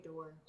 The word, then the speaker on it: Doerr: I would say Doerr. – Doerr, D-O-E-R-R.